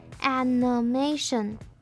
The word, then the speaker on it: animation: 那接下来最后一个字是 animation，这个字的第一个母音是发蝴蝶音 a，那它的第二个音节的那个 i 我们要念成弱化母音 a 的声音，所以这个字会念成 animation。